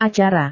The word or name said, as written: acara